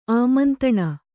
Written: ಆಮಂತಣ

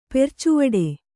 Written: ಪೆರ್ಚುವಡೆ

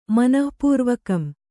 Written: ಮನಃಪೂರ್ವಕಂ

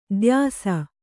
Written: ದೋಯಿಸ

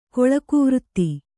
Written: ಕೊಳಕುವೃತ್ತಿ

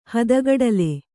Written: ಹದಗಡಲೆ

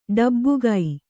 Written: ಢಬಕು

